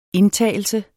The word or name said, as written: indtagelse